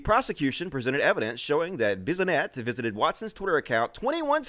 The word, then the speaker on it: Bissonnette: His Twitter account was among a handful of far-right accounts frequented by Alexander Bissonnette.